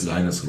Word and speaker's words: Linus: Und da muss man dazu sagen, steht nämlich extra bei Transfermarkt drin, der Vorname wird englisch ausgesprochen. – Linus Rosenlöcher.